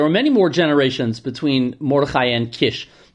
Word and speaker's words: Mordechai: Shmuel Mordechai, some of the Korain types put an extra Mordechai, but Mordechai is how I was raised to pronounce it.